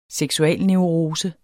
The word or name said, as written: seksualneurose